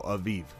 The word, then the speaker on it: Aviv: Aziz or Z's? – Aviv with V's. – Oh, Aviv. Viv? Pam or Pan? Pan? Pan? Pam? Spell it for me. – Aviv. A-V-I-V. – Okay, A-V-I-V. Okay, got it.